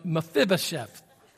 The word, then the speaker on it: Mephibosheth: Did I not saying that right? 50-50. We'll just call him Meth for short. We'll call him Meth. It's Mephibosheth.